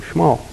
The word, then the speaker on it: Shmuel: Voice comes in to him in the middle of the night and says, Shmuel, that's how you actually say his name, Shmuel. And, not Shmuel, Shmuel.